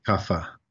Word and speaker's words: Kapha: Yes, Vata, Pitta, and Kapha.